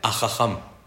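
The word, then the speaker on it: Ajajam (חכם): La palabra inteligente, el inteligente en hebreo, ¿cómo se dice? Ajajam el inteligente. Se escribe Hei, Haf, Het, Mem. Hei, Arroe, Het, Hatzi, Haf, Kos, Mem, Male.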